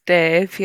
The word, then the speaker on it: Damh: Yeah, I discovered, is it Dan the Bard? – It's Dave, yeah. It's spelled D-A-M-H there.